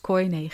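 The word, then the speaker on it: koinè: Anti is kune Grieks, vaak uitgesproken ook als koine Grieks, maar het is kune Grieks, een bijvoeglijk naamwoord voor het Hebreeuwse woord Satan.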